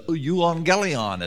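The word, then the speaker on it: euangelion (εὐαγγέλιον): The euangelion is how it's pronounced.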